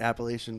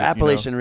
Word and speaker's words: Appalachia: Brett Bass says he never said it, but he said it's like reggae is like Appalachian bluegrass. Like, you know, it's like they all kind of are the same. – Right. That yeah. I mean, it's a kind of folk music. – Or Appalachian, you know? – Reggae.